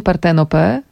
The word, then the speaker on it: Partenope: Bogini Partenope z takim akcentem. – Ale pięknie powiedziałaś.